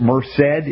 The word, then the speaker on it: Merced: But we got Bakersfield, Visalia, Porterville, Hanford, California, Fresno, Madera, Sacramento, Roseville, San Diego, Carlsbad, Modesto, Merced. Is that how they say it in California? Merced? – Mm-hmm.